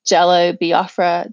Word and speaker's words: Jello Biafra: One is the Game's Jesus Piece, Johnny Cash's The Greatest Cowboy of Them All, and Jello Biafra. Did I say that right? – Yeah. – Okay, Jello Biafra's Jesus Was a Terrorist.